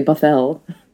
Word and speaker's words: Bothell: Bothell. – So. Bothell, yeah, it sounds less like Bothell kind of rhymes with another word that we don't always use with respect to academia, I suppose.